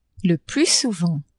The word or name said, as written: le plus souvent